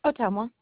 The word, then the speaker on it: Ottumwa: Ottumwa.